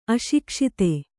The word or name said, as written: ಅಶಿಕ್ಷಿತ